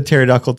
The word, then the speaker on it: pterodactyl: The pterodactyl podcast also has a silent P.